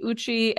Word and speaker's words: Ucci: I'm on Instagram, and that's just my full name, Angela Marie Ucci. And coming back 2.5 hours ago, you did say it right. So. – Oh, I did. Ucci is right. – It's like Gucci without the G.